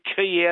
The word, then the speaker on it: quay: How do you get key out of Q-U-A-Y? – You know what?